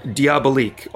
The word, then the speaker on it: Diabolique: Diabolique.